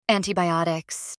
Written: antibiotics

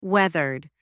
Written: weathered

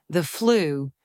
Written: the flu

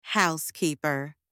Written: housekeeper

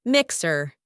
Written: mixer

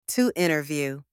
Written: to interview

